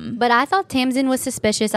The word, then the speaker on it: Tamsin: So I listened to the audiobook and she said Tamsin.